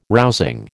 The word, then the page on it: rousing: American English